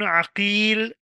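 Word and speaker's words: عَقِيل: For example, Muhammad ibn Aqil بفتح العين and Muhammad ibn Uqail بضمها أي بضم العين.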